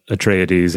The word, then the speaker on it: Atreides: Herbert said Atreides, and he said Harkonnen.